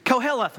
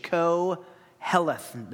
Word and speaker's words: Koheleth: Koheleth, we're going to come back to this word over and over and over.